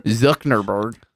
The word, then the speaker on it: Zuckerberg: How do you say it? – Zuckerberg. There's Zuckerberg. – No N.